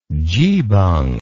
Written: geebung

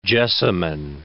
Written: jessamine

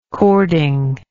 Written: cording

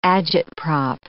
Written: agitprop

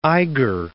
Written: Eiger

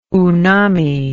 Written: unami

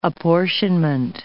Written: apportionment

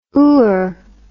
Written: ooer

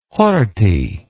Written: horthy

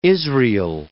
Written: Israel

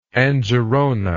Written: Angerona

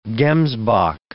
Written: gemsbok

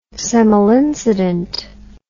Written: semel incident

